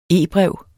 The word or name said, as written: e-brev